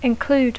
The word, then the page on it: include: English